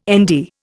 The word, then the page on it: Nd: Indian